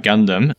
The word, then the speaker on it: Gundam: So I've been to Japan, and I know that people would always go to this place with the giant Gundam. Is that the right way to pronounce it? – Yeah, Yep, that's correct. Giant Gundam.